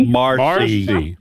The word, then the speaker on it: Marcy: Mercy is on the line. – Me. – Mercy. I like it. Mercy, welcome to Trivia Friday. – It's Marcy. – Marcy. – Yeah. Oh, I butchered that. – I didn't butcher it. It says M-E-R-C-Y right here M-A-R.